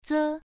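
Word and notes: ze: tone 1

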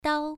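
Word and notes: dao: tone 1

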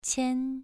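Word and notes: qian: tone 2

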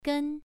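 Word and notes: gen: tone 1

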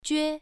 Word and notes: jue: 1

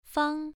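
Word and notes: fang: tone 1

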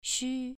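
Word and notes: xu: tone 2

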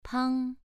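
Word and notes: pang: tone 2

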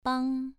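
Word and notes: bang: tone 2

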